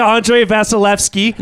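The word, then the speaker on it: Andrei Vasilevskiy: Okay, back to Andre Vasilevskiy.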